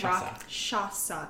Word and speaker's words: Rakshasa: Rakshasa. Think of it like rock lobster. But not Rakshasa. – Say it one more time. Shasa. That's the part I'm having a hard time with. Rakshasa.